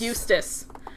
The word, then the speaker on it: Eustace: I think that the inclusion of having Mural and, oh man, I always want to say his name is Houston, but it's not Houston. – Muriel and Eustace. – Eustace, not Houston.